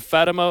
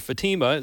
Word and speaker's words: Fatima: This is a woman named Fatima or Fatima, as some people pronounce it, saying she is stranded in Afghanistan and she's pleading for help.